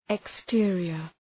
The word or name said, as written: exterior